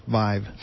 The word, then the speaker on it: VIIV: But I think the big news this week was Intel announced their VIIV technology, which is pronounced Vive.